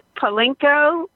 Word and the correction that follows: Poroshenko: I think I pronounced his name correctly. – Poroshenko? Poroshenko. – Poroshenko.